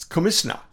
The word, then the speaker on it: Komitina: Komitina, K-O-M-I-T. But that's, remember, in Gilbertese, T-I is pronounced S. It's Komisna, commissioner.